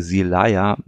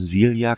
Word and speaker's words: Silja: Ähm, Silja Bishop beziehungsweise Silaya Bishop haben wir beide mal gesagt, sowohl du als auch ich. – Mhm. Ähm, er hat äh, eingeworfen, dass er die Aussprache als Silja kennt und ta tatsächlich lassen sich da auch Beispiele finden von Native Speakern.